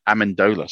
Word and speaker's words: Amendola: His name is Matt, I think it's Alamander. It's Matt Amendola. Sorry, Matt Amendola, I believe, is going to get the starter.